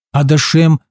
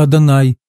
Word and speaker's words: Адонай: Но поверьте, это нисколько меня не волнует, потому что многие иудеи намеренно неправильно произносят божественное имя Господа, называя его Элоким вместо Элохим или Адашем вместо Адонай, чтобы избежать использования святого имени Божьего в суе.